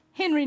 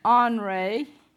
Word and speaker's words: Henri: Henry Nouwen, and if you were a student at Candler, you would pronounce that Henri Nouwen, wrote a book entitled The Wounded Healer.